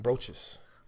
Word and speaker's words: brooches: brooches. How do you say it? – Brooches. – Brooches. Okay, cool.